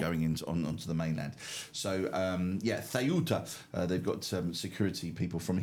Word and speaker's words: Ceuta: Military personnel from Mallorca are helping with the security operation in Ceuta. That's how you pronounce it, apparently. Ceuta, which is the African enclave that is owned by Spain.